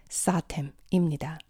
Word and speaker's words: satem: 라틴어로 헌드레드는 C-E-N-T-U-M, 캔텀. 기억하시겠지만 라틴어에서 C는 항상 K 사운드로 발음되었죠. 그리고 다른 하나는 산스크리트어의 헌드레드에 해당하는 S-A-T-E-M, 사템입니다.